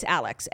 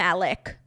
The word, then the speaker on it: Alex: Okay, it's Alex, A-L-E-X. Everyone was calling him Alec, which is just incorrect because there's an X.